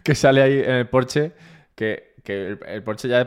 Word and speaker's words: Porsche: Además, cuando dices Porsche, está mal dicho. Es Porsche. O sea, la E se pronuncia, pero bueno, vale.